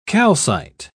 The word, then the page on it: calcite: British English